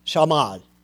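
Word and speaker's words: Shamad (שָׁמַד): Destroy, Hebrew word, shamad. And it's broke down with S, pronounced S-H-A-W, get this, dash, M-A-D. Mad. Shamad.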